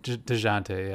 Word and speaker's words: Dejounte: No, I guess maybe he, like, bumped into DeJean, maybe? – Oh, DeJounte. Is it DeJounte Murray? – Yeah. – Okay, yeah. – DeJounte, is that how you say it? – DeJounte, yeah.